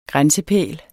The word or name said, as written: grænsepæl